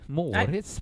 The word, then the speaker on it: Maurits: Jag känner den som heter Maurits och så uttalas det då. Men... – Ja, det känner jag inte till. Moritz nej. Brukar jag säga. – Moritz är ju säkert det naturliga uttalet.